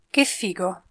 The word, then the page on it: che figo: Italian